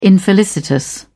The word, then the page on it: infelicitous: British English